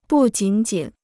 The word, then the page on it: 不仅仅: bù jǐn jǐn